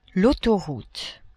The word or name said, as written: autoroute